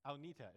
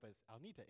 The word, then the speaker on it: Agnete: There's the one girl, Agnita. It took me forever to learn how to pronounce her name correctly, because it's spelled Agnete. So for the longest time, but it's Agnita.